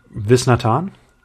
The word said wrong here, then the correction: Viswanathan: Also sie ist ja Australierin, ne? Deswegen hätte ich jetzt gesagt, Viswanathan.